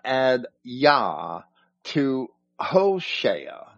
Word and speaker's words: Hoshea: In Hebrew, Hoshea means Savior. You are familiar with it as the name of a prophet who wrote one of the books of the Old Testament. Our English translations pronounce it Hosea.